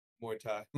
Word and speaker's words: Muay Thai: Muay Thai. How do you say Muay? Is it? – Muay Thai. – Muay Thai. Is that how you say it? – Yeah, Muay Thai.